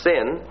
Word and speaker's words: sin: He then proceeded to point out that this lone letter was sin in the Hebrew language, pronounced sin, and furthermore, this Hebrew letter was a word in the Scottish dialect, which means peccatum, Latin for sin.